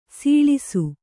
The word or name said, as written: ಸೊಳಸ